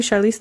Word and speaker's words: Charlize: You don't know who Charlize Theron is? – I mean, I might. I'm... – Now you. Am I saying it right? Charlize. – Yeah, Charlize Theron.